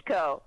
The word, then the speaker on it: Coleco: ColecoVision, yes, the same company. – Coleco, excuse me, I pronounced it wrong.